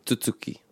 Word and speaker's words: tutuki: Just while we're there with tutuki, tutuki. These types of words, it's like the stress is on the second syllable.